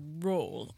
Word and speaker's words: Rolle: And in France, they call it Roll. R-O-L-L-E.